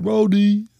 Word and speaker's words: Roti: Is it Roadie? Is that how you say it? – Roadie. That's all I'm saying. – R-O-T-I.